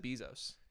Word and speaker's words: Bezos: Hey, his name sounds like bees. You know, he's actually everyone. – Jeff Bezos. – He pronounces it Bezos.